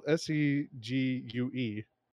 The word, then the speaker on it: segue: Uh, I thought, yeah, I thought it'd be S C G W A Y as well. Okay. Um, it's it's spelled S E G U E.